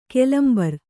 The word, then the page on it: ಕೆಲಂಬರು: kelambaru